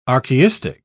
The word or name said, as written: archaistic